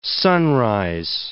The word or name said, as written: sunrise